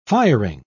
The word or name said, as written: firing